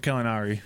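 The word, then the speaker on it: Gallinari: Danilo Calinari.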